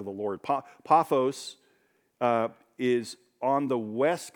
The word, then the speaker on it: Paphos: And when they had gone through the whole island, as far as, uh, there's several ways to say this, paphos seems to be the way, but really paphos is the correct pronunciation.